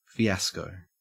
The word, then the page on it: fiasco: Australian English